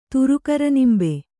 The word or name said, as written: ತುರುಕರನಿಂಬೆ